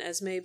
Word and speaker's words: Esme: Is it Esme? Is that how Esme. – You pronounce it? Yeah. Yeah, the only reason why I know that is because of Renesme.